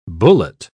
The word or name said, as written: bullet